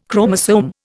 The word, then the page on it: chromosome: Indian